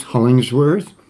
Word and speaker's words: Hollingsworth: Yes, I am Rogers Hollingsworth, H-O-L-L-I-N-G-S-W-O-R-T-H.